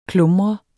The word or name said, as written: klumret